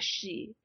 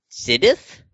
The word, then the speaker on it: Sidhe: They are called the she. It is spelt S-I-D-H-E. – This Siddith? Wait, that's not how you pronounce that. – You say it she.